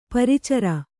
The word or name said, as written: ಪ್ರಚರ